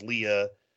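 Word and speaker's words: Lia: Ken Block passed away, and his daughter, I think it's pronounced Leah. – Yeah.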